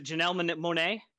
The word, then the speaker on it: Janelle Monae: Dwayne Wade yelling at, was it Janelle Monae? – Yeah. Janelle Monae. – Am I getting the name right? – Yeah. You got it.